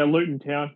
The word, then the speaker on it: Luton Town: Looks like the International Football Cup between Wellington Phoenix, Perth Glory, Luton Town. Is that how you say it? – Yeah, Luton Town.